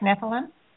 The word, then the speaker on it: Nephilim: Did I pronounce that correct? Nephilim? – Yes, uh, or Nephilim.